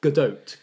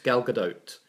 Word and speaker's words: Gadot: Gadot, Gal Gadot.